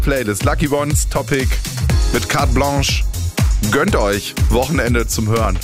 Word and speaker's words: Carte Blanche: und wird aber, Carte Blanche, also französisch ausgesprochen.